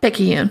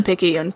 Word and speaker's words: Picayune: Picayune. – Picayune.